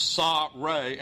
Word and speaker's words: Sa-Re: That is a formulaic title associated with Egyptian kings that it's pronounced Sa-Re, and it means son of Re.